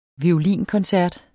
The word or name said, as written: violinkoncert